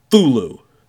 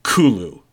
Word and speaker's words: Cthulhu: And without him, also, um, at one point it was said that really it should be pronounced Thulu. Um, but no one pronounces it. They, uh, they all pronounce. Everyone pronounces it Cthulhu, even though that's not. Even though Lovecraft himself uh, pointed out that it probably should be pronounced as, um, as uh, sorry, Kulu. But you know, whatever. Um, or even actually, Kolhu.